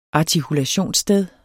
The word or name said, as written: artikulationssted